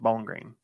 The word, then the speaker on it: Bowling Green: He's like, you don't say it like it's two words. – Bowling Green. – Say it like it's one word. Bowling Green.